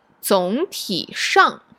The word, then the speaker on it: 总体上: 总体上总体上 Wait, I said that wrong, right? – 对, there's a tone change.